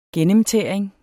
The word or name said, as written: gennemtæring